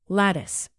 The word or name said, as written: lattice